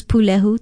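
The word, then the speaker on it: pūlehu: Although it most correctly means broiling, as you would sweet potatoes, breadfruit, or bananas placed on hot embers, we often use pulehu to describe meat that is broiled. It is spelled P-U-L-E-H-U with a stress or kahako on the first syllable.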